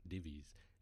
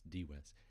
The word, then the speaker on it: Dives: As you can see in chapter 2, we have Lazarus, but Dives, in Latin, a V is pronounced like a W, so it's not Divis, it's Dives.